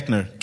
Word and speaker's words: Koechner: Yeah, Koechner.